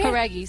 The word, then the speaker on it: Jauregui: Com vocês, Lauren Jauregui. – Did I spell it? Did I pronounce it right? – Jauregui, sim.